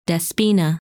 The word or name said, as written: Despina